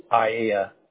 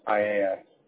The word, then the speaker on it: Aiea: We're going to work our way up, and we're going to get into town called Iaea, which is spelled A-I-E-A. – Okay, A-I-E is an echo, A is an apple. – Yeah, that's spelled, it's Iaea. Iaea.